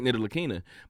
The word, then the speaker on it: Ntilikina: And the reason why is because he mispronounced it and almost repeated him. But it's Frank Ntilikina.